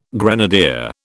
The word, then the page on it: grenadier: British English